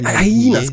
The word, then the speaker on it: Hyenas: Hi, es heißt Highness oder Heines oder sowas. – Hyenas, oder? Hy Hyenas, genau, Hyenas, irgendwie so ganz komisch ausgesprochen.